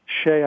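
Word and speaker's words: Hsieh: Tony Shea, I believe is how you pronounce mm-hmm.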